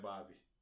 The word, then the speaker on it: Bhabie: Kelly in jail waiting to get out to go fuck Bad Bobby. – What? Why you keep calling her that? – That's her name. Her name is spelled B-H-A-B-I-E. – Bobby. Bad Bobby.